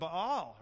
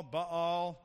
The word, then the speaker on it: Baal: But it's Elijah and the prophets of Baal, right? You can call them Baal, Baal, Baal. I pronounce most of the vowels. I think that's the way it's supposed to be said, Baal.